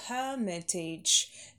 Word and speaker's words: hermitage: Павел, а в устной части Эрмитаж произносим hermitage. Первый ударный шва долгий.